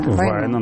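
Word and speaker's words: Вайно: Мы сейчас покажем вам, как это было, как они сегодня встретились с Владимиром Путиным и с товарищем Войно, который его. Вайн. Правильно ударение-то я ставлю? Вайна. – Вайна, наверное, финская фамилия, да. – Вайна, хорошо. Что-то со мной, да.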